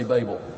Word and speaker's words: Babel: But I'm going to try to remember to say Babel, because I think that's the better pronunciation.